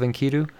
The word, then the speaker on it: Enkidu: And when you approached me with this particular Epics of Enkidu, am I saying that right? Epics of Enkidu? – Yeah, that's what it's called.